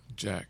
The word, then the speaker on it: Jack: Jack. – How you spell it? Well, how you spell Jack? J A C K. J A C K. Mm-hmm. So you're not Jacques. No.